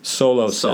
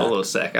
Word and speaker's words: Solosec: Secnidazole and Solosec. – Solosec. I like Solosec. – I like Solosec, yeah. – We'll do that one. – So should we make a little advertisement for them? Solosec, when BV is bothering you.